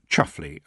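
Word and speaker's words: Chumsfanleigh: Roland de Chum's family, pronounced Chuffley under that strange rule that the more gentrified a family is, the more peculiar the pronunciation of their name becomes.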